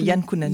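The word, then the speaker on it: Jan Kounen: Je ne sais pas si tu avais vu le film, dans Blueberry, l'expérience secrète de Ran Kunen. Alors est-ce que ça se dit comme ça, Ran Kunen ? C'est Jan Kunen.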